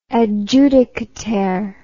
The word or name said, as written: adjudicataire